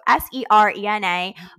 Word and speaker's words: Serena: It's Serena, spelled like the tennis player. So Serena, Marie, R-D.